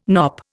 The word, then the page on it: knop: Indian